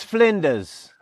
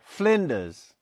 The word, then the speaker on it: Flinders: You know, I believe it's Flinders. You don't pronounce the R. Flinders.